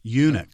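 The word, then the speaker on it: eunuch: No, I'm talking about the, I think I'm mispronouncing the proper name for, Enoch. So it spells E-U-N-C-H. – Unix. – Unix. Okay. – Unix, there you go, Unix.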